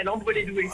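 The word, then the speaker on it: Lambres-lez-Douai: C'est bien ça Oui, à Lambré-les-Doués. – À ah, Lambré-les-Doués, je l'ai mal prononcé. Alors, nous avait contacté pour sa maman.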